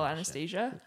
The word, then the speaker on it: Anastasia: And no one apparently knows how to spell Anastasia. – Yeah. Right. – That's 4 syllables.